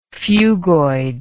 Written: phugoid

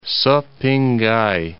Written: Szepingkai

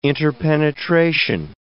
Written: interpenetration

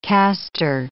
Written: castor

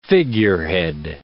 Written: figurehead

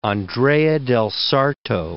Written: Andrea del Sarto

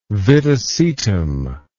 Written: viticetum

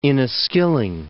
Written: Inniskilling

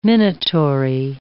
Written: minatory

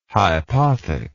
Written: hypothec

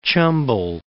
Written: Chambal

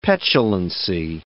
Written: petulancy

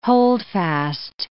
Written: holdfast

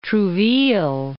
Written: Trouville

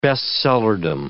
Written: best-sellerdom

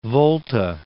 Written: Upper Volta

